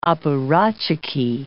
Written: apparatchiki

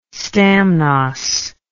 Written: stamnos

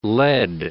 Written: led

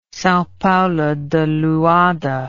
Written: Sao Paulo de Luanda

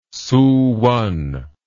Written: Suwon

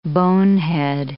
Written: bonehead

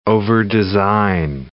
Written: overdesign

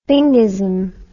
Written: thingism

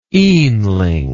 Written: eanling

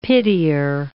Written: pitier

